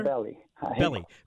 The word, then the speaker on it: Belli: With that, we welcome in Francisco Estrada-Belli or Belli? – Hi, how do you say it, sir? – Belli.